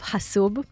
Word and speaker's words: Hasoub: Uh, you are the co-founder and CEO of Hasoub.